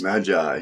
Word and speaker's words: Magi: And today we're going to look at Christ's birth through the wise men's, or how do you pronounce it? Magi's? – Magi, yeah. Yeah.